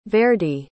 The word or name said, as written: verdi